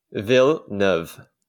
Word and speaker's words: Villeneuve: Two syllables. Yeah, Villeneuve. – It's two syllables. – Mm-hmm. – So I'm going from four syllables to two syllables. – Just call him Denny V. – Denny V. – I don't want to do that. Okay. Yeah. Villeneuve. – It's Vill with a V.